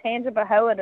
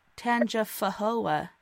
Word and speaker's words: Tangipahoa: And look, you did so good with pronouncing Tangipahoa tonight too.